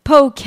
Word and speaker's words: poke: because no one can pronounce it. It's poke, by the way, and it has its roots in Hawaii, of course, the raw food specialty that's taken the dining scene by storm.